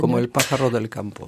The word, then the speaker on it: Como el pájaro del campo: Eh bien écoutez, on va écouter la chanson qui s'appelle Como Pájaro del Campo. Je prononcerai mal espagnol. – Como el pájaro del campo. – Voilà, c'est mieux.